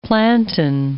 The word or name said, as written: plantain